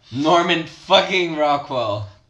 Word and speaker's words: Norman Fucking Rockwell: É, chama Norman Fucking Rockwell. Falei certo? – Sim. O que, que significa isso? – It's a name. Ah, tá. – Norman Fucking Rockwell.